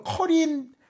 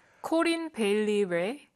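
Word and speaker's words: Corinne Bailey Rae: 어떻게 읽어요? 커린. – 코린 베일리 레.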